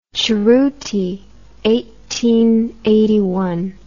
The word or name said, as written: Cerro Tololo Inter-American Observatory